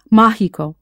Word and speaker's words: mágico: So if basic becomes básico, how would you say magic? Of course, mágico, with that accent over the first syllable.